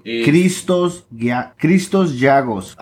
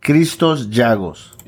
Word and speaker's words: Christos Giagos: Christos Giagos. That's how I'd pronounce it. Christos Giagos.